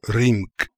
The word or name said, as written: Rimk